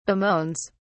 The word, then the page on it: bemoans: British English